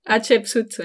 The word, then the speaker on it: Hatshepsut: Après, du coup, on a fait le temple de Hatshepsut. Hatshepsut, ouais.